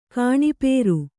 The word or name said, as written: ಕಾಣಿಪೇರು